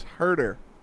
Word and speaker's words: Huerter: Kevin, learn how to pronounce Kevin Huerter. It's Herder. Play it. Kevin Herder.